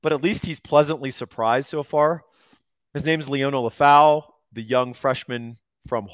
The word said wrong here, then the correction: Leonga: I think there's a G in there, a silent G. Or no, a invisible G. – Lefau? – Leonga Lefau. – Oh, Leonga Lefau. Oh, yeah. I say Leona like a lion.